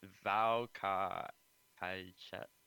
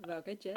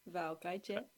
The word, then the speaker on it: Vào cái chết: It's the name in Vietnamese. Is your Vietnamese good? Maybe you can say it. Vào cả hai chat. VAOCAICHET. – Vào cái chết. – I'm after. How do you say it? – Vào cái chết.